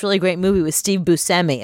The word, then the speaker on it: Buscemi: Oh, actually, we've been saying Steve Buscemi's name wrong. – What is it? Todd Frank? – He literally just told us recently that it's Buscemi.